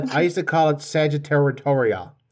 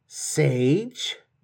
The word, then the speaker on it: Sagittarius: And I used to call it Sagittaritoria and Sagittaritaritans. And I used to say all kinds of silly things. And then someone was like, no, Sagittarius.